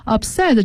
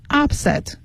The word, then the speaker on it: upset: 好，这里面还出现了 upset U-P-S-E-T upset 这个单词的重读是在 set 上，而不念成 upset，它的重音是 upset 在 set 上。